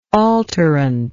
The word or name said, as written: alterant